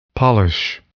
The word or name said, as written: polish